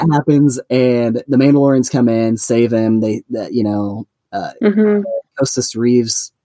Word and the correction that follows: Koska Reeves: is I saying that right? Koska Reeves comes in and saves the child.